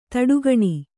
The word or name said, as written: ಥಟಕ್ಕನೆ